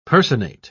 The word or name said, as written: personate